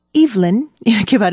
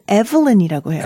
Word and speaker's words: Evelyn: 영국에서는 Evelyn 이렇게 발음하는데 액센트가 앞에 있네요. – 네, 미국에서는 Evelyn이라고 해요. – Evelyn. 네, 스펠링은 같은 좀 이름, 다른가요? – 아, 스펠링은 똑같은데 발음이 다릅니다.